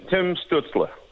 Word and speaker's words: Stützle: You know, obviously Lafreniere, Byfield, and is Stützle, give us the pronunciation. – Yeah, Tim Stützle.